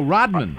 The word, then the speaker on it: Rodman: It's R-O-D-M-A-N. – Oh, Rodman.